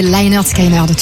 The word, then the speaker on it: Lynyrd Skynyrd: Ma mère disait Lynyrd Skynyrd, tu vois, il y a plein de... – Oui, parce que ça s'écrit avec un Y, mais sur leur premier album, l'album s'appelle Pronounced Lynyrd Skynyrd.